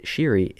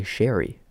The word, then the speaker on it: Sheary: And I didn't know until last night that Sheary isn't the way you pronounce it. It's actually Sherry.